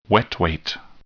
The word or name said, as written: wet weight